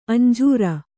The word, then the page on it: ಅಂಜೂರ: kannada